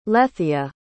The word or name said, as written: lethia